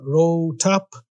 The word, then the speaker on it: RoATP: This time, we're going to be talking about RoATP, ROAT, depending on how you pronounce it, R-O-A-T-P,